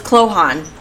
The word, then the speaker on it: Cloghaun: And it's spelled C-L-O-G-H-A-U-N. So I am so grateful that last year I met them, because I would not have, I think I called it Klohan. I didn't know how to pronounce it. So it's Klohan.